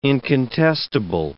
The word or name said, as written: incontestable